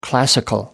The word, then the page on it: classical: American English